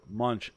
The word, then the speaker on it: Monch: It's P-H-A-R-O-A-H-E. And then second word, Munch, M-O-N-C-H.